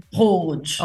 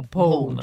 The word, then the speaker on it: Bhoj: B-H-O-J, right? How do you pronounce it? – Bhoj. Yes. Poge. – Oh, Poge.